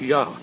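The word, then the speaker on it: Ya (Я): Well, it so happens, as I pointed out, dida dida does represent a letter in the Russian alphabet, the one that looks like a backward R, pronounced Ya.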